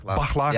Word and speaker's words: Baklar: Spreek je achternaam goed uit, Baklar? – Ja, Baklar. Ja. Dat is helemaal goed.